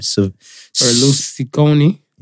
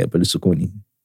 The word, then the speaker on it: Berlusconi: Berlusconi. – Yeah, Berlusconi.